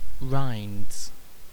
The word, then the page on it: rinds: British English